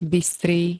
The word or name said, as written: bystrý